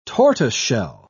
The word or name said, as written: tortoiseshell